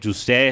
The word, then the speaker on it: José: Que José es la manera como decirlo en, en, en, portugués.